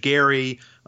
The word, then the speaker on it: Gerry: The governor of Massachusetts at the time was Elbridge Gerry. The soft or the hard G became a soft G over time.